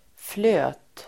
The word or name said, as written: flöt